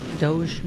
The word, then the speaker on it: Doge: Doge? Am I saying it right? – You're saying it right.